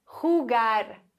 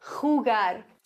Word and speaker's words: jugar: Jugar. Achtung, hier haben wir ein J, das wird ausgesprochen so ein bisschen wie ein CH. Das muss kratzen im Hals. Jugar.